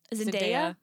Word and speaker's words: Zendaya: Zendaya.